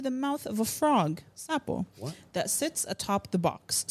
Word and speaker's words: Sapo: Sapo. S-A-P-O.